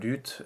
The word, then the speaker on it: lutte: And here we would actually say the T. This is not a liaison. – Yeah, lutte. No, it's not a liaison. Lutte, that's the way you pronounce the word. Lutte, the T is not silent.